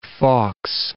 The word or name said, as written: Fawkes